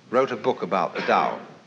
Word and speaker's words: Tao: So their principle of nature is called the Tao, T-A-O, pronounced Tao in the Mandarin dialect, Tao in the Shanghai dialect, To in the Cantonese dialect.